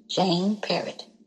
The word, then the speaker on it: Jane Parrot: J-A-Y-E-S. Love for God. And also I have a Facebook page, Jane Parrot. J-A-N-E. Parrot. Spell that like the bird, only with one T.